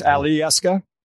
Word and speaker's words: Alyeska: In Alaskan Native pronunciation, all the vowels are pronounced. So it's Alyeska and it's Aleut for both of those.